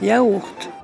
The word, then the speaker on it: yogourt: Ça s'écrit yogourt mais eux ils prononcent yaourt.